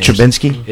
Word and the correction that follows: Trubisky: It's Trubisky. – Trubisky. Okay. Trubisky.